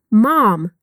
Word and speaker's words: mum: Aqui no diálogo ele diz mom, que se escreve M-O-M. Já no inglês britânico seria mum, escrito M-U-M. Então vamos repetir de novo no inglês americano: mom. E agora no britânico: mum.